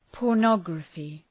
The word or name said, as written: pornography